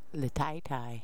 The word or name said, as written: leteitei